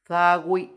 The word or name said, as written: dhagwi